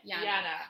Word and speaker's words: Jana: Jana. So, like, the J is, like, a Y, I guess.